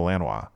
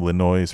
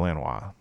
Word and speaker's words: Lanois: which is Daniel Lanois. And some people pronounce his last name Linois or whatever, looks like whatever.